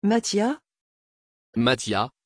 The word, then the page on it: Matthia: French